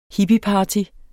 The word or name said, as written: HIPPIEPARTY